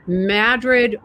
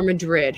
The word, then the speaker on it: Madrid: Lastly, before you go, is it Madrid or Madrid? How do you pronounce it? – Oh, I was so hoping that you would ask, because I'm in a camp that is the smallest camp of all about the pronunciation, which is that I strongly, strongly believe that both are fine.